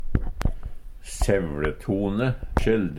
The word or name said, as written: SevLetone